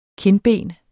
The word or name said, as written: kindben